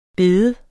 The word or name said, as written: bede